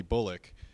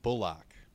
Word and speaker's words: Bullock: And then the other guy that kind of went on a streak for a minute was Reggie Bullock. Bullock. Sorry. They mentioned that they changed the pronunciation of his name at some point in the NBA media guide, and I'm still messing it up.